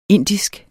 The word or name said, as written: indisk